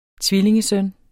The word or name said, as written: tvillingesøn